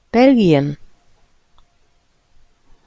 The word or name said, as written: belgien